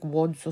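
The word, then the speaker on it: Łódź: I think it's pronounced like Wodz or something like that because they don't pronounce the L's there.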